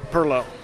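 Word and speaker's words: perloo: Perlo, or most of us who live outside of St. Augustine would probably pronounce it Pilau. But we got roundly castigated, Craig and I, when we first arrived there and called it Pilau, and everybody knew we were Yankees.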